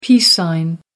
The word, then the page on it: peace sign: American English